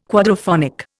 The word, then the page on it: quadraphonic: Indian